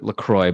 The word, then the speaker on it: La Croix: So they say on their website, you should pronounce it La Croix, but you're right. It's a fucking French word.